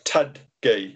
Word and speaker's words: Tudgay: Alan Tuggay. – How do you spell that? Tug, what's that? – T-A-T-U-G. – Right. – T-U-D-G-A-Y. Tuggay.